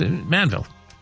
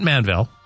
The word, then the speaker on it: Manville: Yeah, it's Manville. Not Manville. Like, you know, Manville. Manville's wrong. Manville's right. That's how that one works.